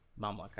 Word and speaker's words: Mamluk: Saladin has definitely got the best unique cavalry unit with the Mulmax or Mulmuluk? Mamluk? Yeah, Mamluk. I just looked it up to make sure I was pronouncing it right.